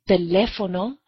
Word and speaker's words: teléfono: Some common examples are the word teléfono. The word ends in a vowel, but the natural stress is not on the next to the last syllable, so a written accent is needed.